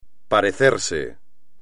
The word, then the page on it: parecerse: espagnol